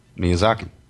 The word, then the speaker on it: Miyazaki: The two movies I've seen of Miyazaki.